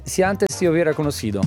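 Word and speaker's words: Si antes te hubiera conocido: Si antes ti hubiera conosciuto.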